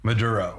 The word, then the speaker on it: Maduro: And I mean, we saw it with the Mandora, like, am I pronouncing that right? With the Venezuelan, it was instead of what? – Maduro. – Maduro, sorry.